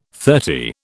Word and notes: thirty: British English

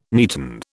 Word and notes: neaten: British English

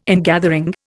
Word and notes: ingathering: Indian

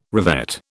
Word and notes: revet: British English

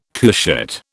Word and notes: couchette: British English

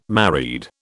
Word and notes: married: British English

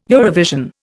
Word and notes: Eurovision: Indian

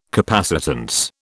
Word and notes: capacitance: British English

